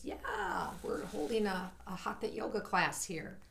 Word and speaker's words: hatha: Why are you pronouncing it like that? I thought it was hatha yoga. – Hatha yoga. – Hatha yoga is kind of the American way. Hatha is more of a Sanskrit way.